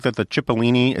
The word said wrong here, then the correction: Cipollone: Oh, Cipollone?